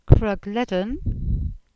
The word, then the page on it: Krugledan: Cornish